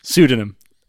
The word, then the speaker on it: pseudonym: Pseudonym. Pseudonym. We stopped the podcast for me to read that word, tell you what it was. I told you how to pronounce it. You said, is it Synodium? I said, no, it's pseudonym.